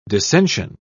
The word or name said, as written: dissension